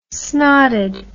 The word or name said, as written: snotted